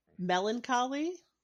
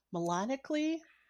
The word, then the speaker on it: melancholy: Like I almost think they're two different words like uh melancholy. – Okay. – I, I always thought was it I just totally added extra syllables in, but I thought it was melanically.